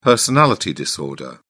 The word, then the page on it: personality disorder: British English